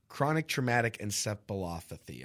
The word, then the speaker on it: chronic traumatic encephalopathy: Chronic traumatic encephalopathy, I believe, is the way it's pronounced.